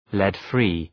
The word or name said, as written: lead-free